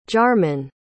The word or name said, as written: jarmann